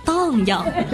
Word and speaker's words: 荡漾: 啊，请注意我的发音是荡漾。